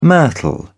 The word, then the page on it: myrtle: British English